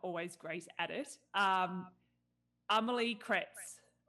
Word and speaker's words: Amélie: You put like an A after the M. That's the first thing people do, because there's like an accent on the E, so it's Amélie. – So, Amélie.